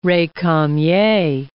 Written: recamier

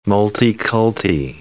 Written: multiculti

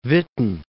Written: Witten

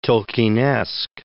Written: Tolkienesque

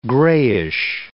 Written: grayish

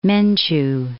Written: Menchu

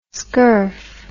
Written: skurf

